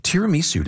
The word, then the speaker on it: tiramisu: Tiramisu. Did I say that properly? – Oh, you did.